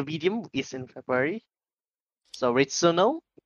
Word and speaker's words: Returnal: The only game that you're going to get for the PS5 is Returnal. How do you pronounce that? Returnal? – Returnal.